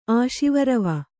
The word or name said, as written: ಆಶಿವರವ